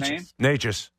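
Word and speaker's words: Nečas: Yeah, and look, Neshkash, is that how you pronounce his Natchez. – Name? Natchez.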